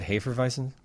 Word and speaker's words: Hefeweizen: Is that how you say it, Hefeweizen? – Hefeweizen, yep.